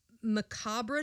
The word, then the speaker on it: macabre: He is now remembered for his macabre tales. Is that how you pronounce macabre? – That? Macabre. That's what I knew that, but it doesn't look like macabre.